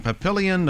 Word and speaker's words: Papillion: In Papillion, Nebraska? – Yes. – Is that the way you pronounce that, your hometown? – Uh-huh.